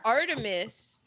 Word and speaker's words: Artemis: And she says how the hunter Actaeon, butcher that name, he stumbles across the goddess Artemis, A-R-T-E-M-I-S. I've seen Artemis. – That. Yeah, Artemis. While Artemis. She's bathing. And she gets mad and turns him into a stag. – Mm. – And the name Artemis in by the Romans is Diana.